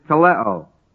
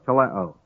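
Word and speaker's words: teleo (τελέω): It's teleo, spelled T-E-L-E, long O, teleo. T-E-L-E, long O.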